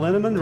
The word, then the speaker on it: Linneman: Val Linneman, Rhymes with Cinnamon,